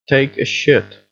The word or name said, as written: take a shit